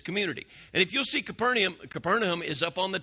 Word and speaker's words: Capernaum: Capernaum is how we pronounce it, but that's actually not how it's said. It's actually said Capernaum.